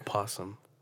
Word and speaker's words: opossum: And they found a spider dragging a fucking opossum. Like a good size opossum. – It's called possum. – Opossum? No, it's possum. What? – It has the A is silent in the beginning.